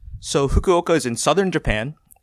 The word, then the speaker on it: Fukuoka: Fukuoka. Fukuoka.